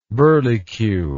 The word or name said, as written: burleycue